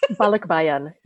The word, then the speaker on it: Balikbayan: You begin the chapter with this: in the Philippines, I am an Americano, a returner, a Balikbayan. That is a Tagalog word probably that I don't know how to pronounce. – Balikbayan.